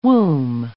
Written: womb

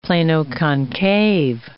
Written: plano-concave

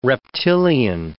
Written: reptilian

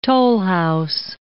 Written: tollhouse

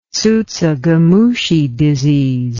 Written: tsutsugamushi disease

